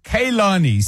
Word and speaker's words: Kehlani: and the pronunciation guidance says Kehlani.